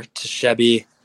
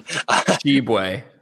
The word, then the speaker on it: Tshiebwe: Oscar Tshiebwe, um, I said that wrong. Tshiebwe. yeah, that's right.